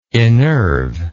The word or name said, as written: innerve